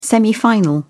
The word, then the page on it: semifinal: British English